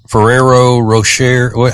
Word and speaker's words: Ferrero Rocher: Ferrero Rocher.